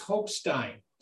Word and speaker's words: Hochstein: And I actually wrote down, that name gets mispronounced. It's whatever. It's like, no, it's Hochstein.